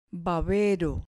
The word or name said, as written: babero